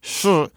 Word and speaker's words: shi: You can imagine it's spelled S-H-I and it's pronounced shi.